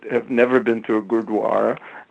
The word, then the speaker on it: gurdwara: That was a very very well done with the pronunciation of gurdwara, so commend you on that, actually.